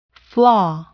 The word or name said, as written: flaw